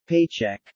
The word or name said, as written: paycheck